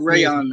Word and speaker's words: Reyon: It's pronounced Reyon, though.